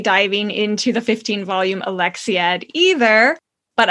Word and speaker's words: Alexiad: I mean, hello, she wrote something called The Alexiad.